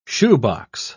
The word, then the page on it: shoebox: British English